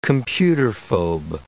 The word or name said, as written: computerphobe